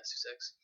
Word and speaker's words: Sussex: Yeah, Sussex.